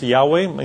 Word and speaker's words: Yahweh: And L-O-R-D is the personal name of God, most likely pronounced Yahweh, in some of your Bibles, Jehovah.